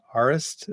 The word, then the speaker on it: Arist: It's called, I think it's pronounced Arist. It's A-R-I-S-T.